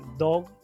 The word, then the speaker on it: Dawg: por el hecho de que él, esto de Dog, que está escrito D-A-W-G, pero es como cuando lo lees, cuando lo pronuncias, está diciendo Dog como de perro.